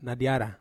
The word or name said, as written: nadiara